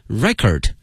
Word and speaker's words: record: 那么这个词的发音同学们要小心了，作为名词呢它的念法是 record record，如果作为动词呢变成 record record。